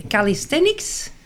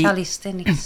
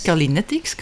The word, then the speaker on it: calisthenics: We hebben een workout sessie, calisthenics. – Niemand weet goed dat je dat moet uitspreken. Zo die calisthenics. – Calinetics, calisthenics.